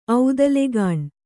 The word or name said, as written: ಔದಲೆಗಾಣ್